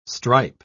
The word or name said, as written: stripe